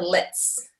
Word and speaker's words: LLETZ: She did this thing called like a LETS, like it's spelled LLETZ, and basically they just remove some cells that have shown to be precancerous.